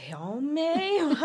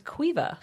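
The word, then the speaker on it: Caoimhe: And that one, the C-A-O-I-M-H-E, I was like, Kaome, what? And it's Cuiva.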